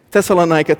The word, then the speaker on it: Thessalonica: The Bible says that when Paul goes to Thessalonica, Pastor Gary taught me how to say this. Thessalonica. Thank you, Pastor Gary.